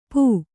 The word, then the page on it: ಪೋ: pō